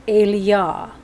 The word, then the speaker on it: Elijah: So Elijah is Eliah. That's from the Strongs.